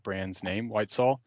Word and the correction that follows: Whitesville: It's Whitesville, mate. – Whitesville.